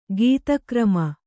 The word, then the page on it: ಘಾತಿಕರ್ಮ: ghāti karma